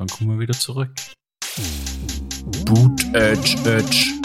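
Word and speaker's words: Buttigieg: Ähm, ja, der Pete, Mayor Pete Boot, Edge, Edge. Ähm, so spricht man aus.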